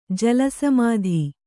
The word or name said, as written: ಜಲಸಮಾಧಿ